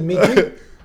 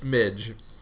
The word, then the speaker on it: midge: Is it midgy? – I say midgies, but I've never heard midgy before. It's midge. – That's really? What I always heard. Yeah, midge. Yeah.